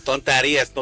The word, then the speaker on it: tonterías: Tonterías.